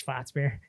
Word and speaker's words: Schwarzbier: And we have PTSD from the pronunciation, because every time we said Schwarzbier, Jim Adams was like Schwarzbier. – He's like, Schwarzbier. – Actually, guys, it's Schwarzbier.